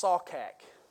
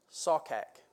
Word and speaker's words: sachaq: It's the Hebrew word, for those of you who like to study, it's this uh, Strong's number 7832, and it's, it's pronounced Sawkak. Sawkak. S A C H A Q.